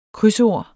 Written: krydsord

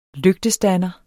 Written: lygtestander

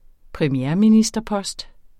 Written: premierministerpost

